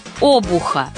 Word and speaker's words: обух: А как правильно? «Обух» и «обух» – равноправные варианты. У Аксакова, конечно, ошибки нет, там поэзия. А вот в пословице ударение только одно – плетью обуха не перешибешь.